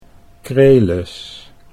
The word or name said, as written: Krelis